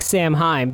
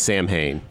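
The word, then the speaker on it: Samhain: I mean, it looks like Sam Heim, but my understanding it's pronounced like. S- not like that, so I don't even want to try. – It's Sam Hain.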